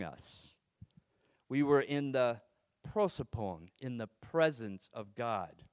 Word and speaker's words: prosopon: So now if we jump over to the New Testament, in the New Testament, the Greek word is prosopon, and I'm going to spell that. P-R-O-S-O-P-O-N.